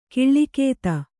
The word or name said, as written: ಕಾಲಗತಿ